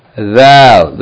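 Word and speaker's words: ذال: thal the letter thal yani we said that this letter is pronounced like th in this or that or these or those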